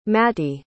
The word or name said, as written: mattie